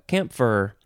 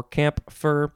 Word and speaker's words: camphor: Now we have camphor. You can sort of pronounce the P if you want, camphor or campfer. C-A-M-P-H-O-R, noun from the 14th century,